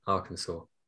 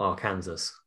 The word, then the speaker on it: Arkansas: Oh, it's Arkansas, isn't it? Arkansas. – Yep. – Not Arkansas.